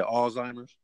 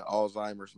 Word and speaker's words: Alzheimer's: But well, you said Alzheimer's? – Yep. – Okay, got you. – Oh, yeah, yeah, Alzheimer's, man.